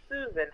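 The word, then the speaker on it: Suzan: So my first name, even though it's spelled with a Z, it's pronounced Susan, S-U-Z-A-N, Z, so you can remember me.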